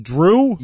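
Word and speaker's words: Jrue: J-R-U-E I don't. – Is pronounced Drew? – Yeah, like Chris Bryant playing for the Cubs today. It's not C-H-R-I-S. I believe it's K-R-I-S.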